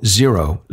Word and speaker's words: Xero: That's X-E-R-O, Xero. It sounds like X-R-O, but it's, I think, pronounced Xero.